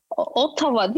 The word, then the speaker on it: Ottawa: Koskoca videoda 45 dakika atıyorum Ottawa'yı anlatıyorum. Videoda Ottawa demişim. Vay efendim ben niye Ottawa demişim ama adı Ottawa'ymış. – Ottawa. – Ottawa değil mi yani doğrusu? – Evet, evet.